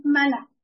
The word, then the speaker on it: manna: 就在这个地方，神加了一句，神赐给他们的那个 manna 啊，其实应该发音成 manna，因为这有点像我们人看见一个不认识的东西会说慢呀慢呢，所以它就是这么个音啊。